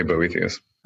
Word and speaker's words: Boethius: Boethius book or Boethius book, depending on how you want to pronounce it. How do you pronounce it? – I would say Boethius.